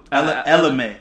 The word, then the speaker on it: Ella Mae: How you pronounce Shorty's name? – Who? Ella Mae.